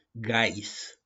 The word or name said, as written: GAIS